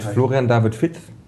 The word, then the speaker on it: Florian David Fitz: mit Florian David Fitt. Und das ist die korrekte Aussprache. – Ja, das ist die korrekte Aussprache nämlich.